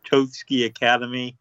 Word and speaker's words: Totsuki Academy: I can't pronounce it. Totsuki Academy. – Yeah, you pronounced it fine.